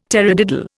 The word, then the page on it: tarradiddle: Indian